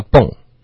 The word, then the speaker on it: bon: Bon voyage. – Bon voyage. – Good. Good. – 什么意思嘞？ – bon voyage 是法语，然后它其实它的发音就第一个字呢，英文会发成 bon，你看到 bon 就觉得应该这么发。 – 好的。 – 法语应该是发蹦。是一个比较有点像蹦蹦车的蹦。